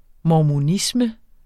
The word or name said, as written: mormonisme